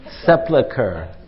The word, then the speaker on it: Sepulchre: Holy Sepulchre, is that how you say it? A little louder, somebody. – Sepulchre.